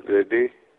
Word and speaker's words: Reddy: My name is Reddy. – Spell it, please. – R-E-D-D-Y. – R-E-D-D, right? – Yeah.